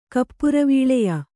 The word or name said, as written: ಕಪ್ಪುರವೀಳೆಯ